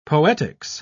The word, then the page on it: poetics: British English